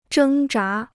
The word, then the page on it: 挣扎: zhēng zhá